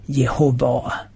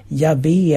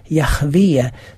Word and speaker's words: Jahve: På så sätt så fick vi faktiskt det uttal som också finns sedan gammalt, även det i många kyrkor och med lite olika variationer i olika länder där det då står Jehova. Idag förekommer ofta uttalet Javé eller Jahve som anses vara mer ursprungligt.